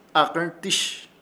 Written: AKƗRTƗSH

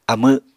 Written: amw

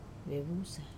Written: vusa

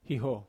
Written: haho

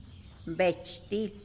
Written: betch til

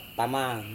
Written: tamang

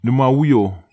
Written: nemawio

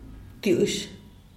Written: btush

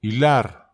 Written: ilar

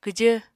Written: kwjv

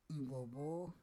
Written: ivovo

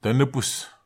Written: tanepes

